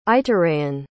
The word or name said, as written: ituraean